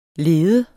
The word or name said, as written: leder